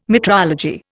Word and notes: metrology: Indian

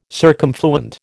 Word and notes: circumfluent: American English

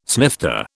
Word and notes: snifter: British English

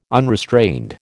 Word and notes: unrestrained: American English

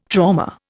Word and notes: trauma: Indian

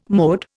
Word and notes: mode: Indian

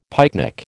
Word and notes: pycnic: American English

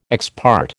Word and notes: ex parte: American English

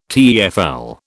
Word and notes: TEFL: British English

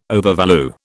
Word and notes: overvalue: British English

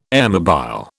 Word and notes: airmobile: British English

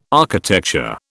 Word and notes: architecture: British English

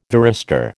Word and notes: varistor: American English

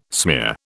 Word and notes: smear: British English

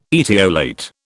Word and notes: etiolate: British English

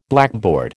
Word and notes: blackboard: American English

